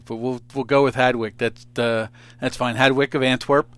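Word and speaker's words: Hadewijch: You got to help me with, is it Hadowich? Is that how it's pronounced? – Yeah, some people pronounce it differently. I think it's more like Hadwick. – Okay. – I think it's a harder sound, but you're okay.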